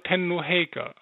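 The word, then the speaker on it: Tennō Heika: Wenn man jetzt denn den Namen sagen wollen würde, wie würde man ihn konkret richtig aussprechen? – Genau, es wäre Tenno Heike, also Ihre Majestät der Tenno.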